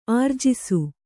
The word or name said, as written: ಆರ್ಜಿಸು